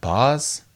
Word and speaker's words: Bas: It's a song called Night Job by, I think it's pronounced Boz, B-A-S? – Yeah. – Boss? – I think so.